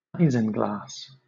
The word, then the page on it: isinglass: British English